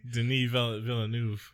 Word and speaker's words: Denis Villeneuve: You don't know how to say his name? – I am terrible with names. How many times? – His name is Have you met me? – Denis Villeneuve. He's a Quebecois, obviously.